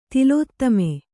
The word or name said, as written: ತಿಲೋತ್ತಮೆ